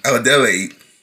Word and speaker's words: Adelaide: where we learn that Adelaide, I think I pronounced it.